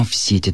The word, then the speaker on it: сети: Правильная форма в сети, то есть ударение при склонении лучше сохранять на корне.